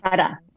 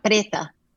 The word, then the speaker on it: Preta: Preta. – Catta Prada. – Catta Prada. – So I wasn't Preta. Close. Preta. – Preta. Like it's like the word Catta in Spanish. Preta.